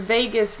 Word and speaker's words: vagus: And actually, it's kind of cool that it's called the vagus nerve, even though it's spelled differently. It's spelled V-A-G-U-S. But you can think about it as like your lifeline for this party, right? People party in Vegas all the time.